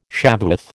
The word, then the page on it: Shavuoth: American English